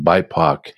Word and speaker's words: BIPOC: It's pronounced BIPOC. It's like, no, it's not Spock's brother, but BIPOC.